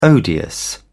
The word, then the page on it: odious: British English